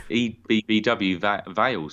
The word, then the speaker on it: Ebbw Vale: EBBW Vale, sorry. – And it's not even Valley, it's Vale.